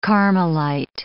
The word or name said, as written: Carmelite